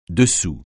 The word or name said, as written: dessous